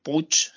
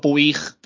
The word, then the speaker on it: Puig: Roch, eh, Benjoch, eh, pues Puch, para mí es Puch, ¿no? Es un apellido valenciano, pero cuando escucho Puig, pues no sé, al final, bueno, es cubano y la gente, más los americanos que pronuncian de aquella manera.